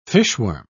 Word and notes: fishworm: British English

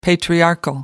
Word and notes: patriarchal: American English

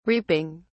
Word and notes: reaping: British English

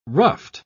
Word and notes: ruffed: British English

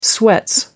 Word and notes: sweats: British English

